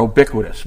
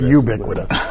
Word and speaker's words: ubiquitous: Or ubiquitous, whatever. I said it right that time, didn't I? – No, it's ubiquitous.